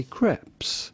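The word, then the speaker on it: crêpes: In French, we would say crepe. – Crepe. – Crepe. The S at the end in French is silent, but in English or American, it'd be fine to say crepes.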